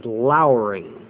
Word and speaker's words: lowering: You say it'll rain, but the proper pronunciation is lowering, to lower.